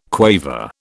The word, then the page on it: quaver: British English